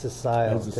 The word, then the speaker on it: Cecil: It's pronounced Cecil. – Saying it wrong? – Yeah. Cecil. Yeah, Cecil.